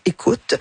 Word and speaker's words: Ekhout: Ça s'écrit E-K-H-O-U-T. Donc, Anne écoute.